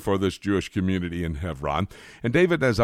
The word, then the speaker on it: Hebron: Now, you may hear me pronouncing it Hebron, H-E-V-R-O-N. You may spell it, and in the South, they have a bunch of cities named Hebron, H-E-B-R-O-N. But the B has a V sound in Hebrew, for that's the purpose for which that we are using that terminology.